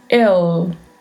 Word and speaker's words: el: A pronúncia deste L, que é com a língua entre os dentes, el, el.